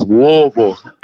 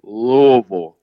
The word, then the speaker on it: Louisville: No, you gotta say it's Louisville. It's not Louisville. It's not Louisville. It's not whatever you want to call it. It's Louisville.